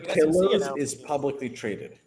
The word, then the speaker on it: Portillo's: Can you, as a Chicagoan, can you tell Andrew how to say Portillo's? – Portillo's.